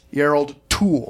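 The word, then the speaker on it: Jarl Kulle: The lead performance here by Yarl Kool, if I'm pronouncing that correctly, whom we saw previously in The Devil. – Proper pronunciation is Yarl Tool.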